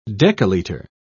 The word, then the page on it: decalitre: British English